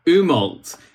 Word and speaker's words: Umault: Umault.